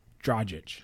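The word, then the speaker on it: Dragić: Dragic. It's not g, it's j. Dragic.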